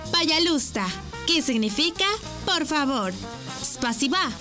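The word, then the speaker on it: пожалуйста: Y se dice payalusta. ¿Cómo? Payalusta. Una vez más. Payalusta. Y se escribe payalusta. P-A-Y-A-L-U-S-T-A.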